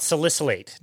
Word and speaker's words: salicylate: So I did want to ask you about salicylate. First of all, did I say that correctly? – You're good with all the big words.